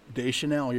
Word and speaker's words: Deschanel: And I'm probably going to butcher her last name, but Emily Deschanel. – Deschanel, you're right.